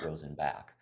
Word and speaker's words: Rosenbach: the Rosenbach. Am I saying that right? – we that is the correct German pronunciation. We actually pronounce it Rosenbach, because that's how the family ended up sort of.